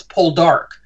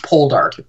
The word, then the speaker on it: Poldark: It's Poldark. The emphasis is on the last syllable, not Poldark.